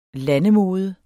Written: landemode

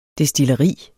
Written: destilleri

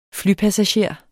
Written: flypassager

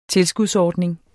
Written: tilskudsordning

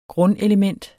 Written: grundelement

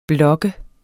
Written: blok

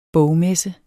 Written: bogmesse

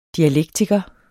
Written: DIALEKTIKERE

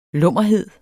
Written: lummerhed

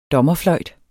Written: DOMMERNE

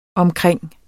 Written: omkring

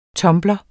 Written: TUMBLERE

